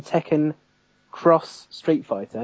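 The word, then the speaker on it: Tekken X Street Fighter: So Namco is making Tekken Cross Street Fighter. It's got an X rather than, but it's pronounced Cross.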